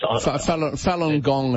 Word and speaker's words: Falun Gong: There's about 60-odd people down there at the moment holding banners saying stop the persecution of Feng Guan, I think it's pronounced. – Falun Gong it'll be.